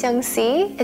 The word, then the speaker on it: Yunxi: Hi, my name is Divya, and that's spelled D-I-V-Y-A. – Hi, my name is Yungsi. It's spelled Y-U-N-X-I. – My name is Suchitha, and that's spelled S-U-C-H-I-T-H-T-H-A.